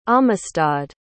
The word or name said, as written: amistad